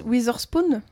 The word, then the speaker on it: Witherspoon: Euh, Witherspoon.